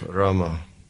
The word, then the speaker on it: Rama: And it said that it's two syllables, Rama. – Там два слога Рама. – And the first syllable Ra, what it does is it throws all sin away from your heart. – Первый слог Ра, он изгоняет, очищает ваше сердце от всех грехов. – And the second syllable Ma blocks the sin from again coming inside the heart.